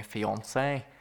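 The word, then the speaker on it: fiancé: If you can't tell what I'm saying, I'm actually saying a fiance. – Yes. – Rhymes with Beyonce.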